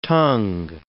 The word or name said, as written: tung